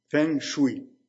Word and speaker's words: Feng Shui: Feng Shui, ça s'écrit F-E-N-G. Vous pouvez faire des recherches là-dessus. F-E-N-G. Et un autre mot, S-H-U-I. Feng Shui.